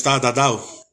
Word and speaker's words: Tatau: Tatau. And it's Tatau. The word tattoo comes from the word Tatau.